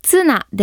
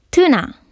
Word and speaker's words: tuna: マグロは英語で tuna ですが、発音に注意してください。ツナではなく tuna。